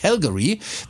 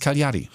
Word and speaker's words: Cagliari: Heißt ja nicht Calgary, weil man kommt von Calgary auf. Wie hast du es gerade gesagt? – Cagliari, ja. – Nee, ist es aber nicht. Es heißt Cagliari.